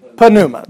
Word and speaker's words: pneuma: But how would you say it in the Erasmian pronunciation? Pneuma. You would say pneuma because you wouldn't leave out the P because we pronounce every letter, right?